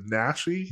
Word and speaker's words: Naschy: but Paul, is it Nashi? – Yes.